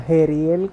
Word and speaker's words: Jeriel: Pues a mí me pueden seguir como Jeriel Cuadrado. Se escribe con J, Jeriel Cuadrado.